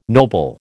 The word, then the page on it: noble: American English